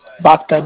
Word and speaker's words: Bhakta: Yogi Bhakta. – How do you spell that? – Y-O-G-I. – Okay, Yogi. Got it. And how do you spell your last name? – Bhakta. B-H-A-K-T-A.